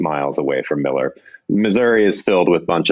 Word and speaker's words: Missouri: Some people might pronounce it Missouri, but it's Missouri.